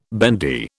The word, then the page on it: bendy: British English